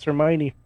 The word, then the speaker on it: Hermione: I heard a, I heard a lot of people pronounce Hermione Hermione until they oh, heard it.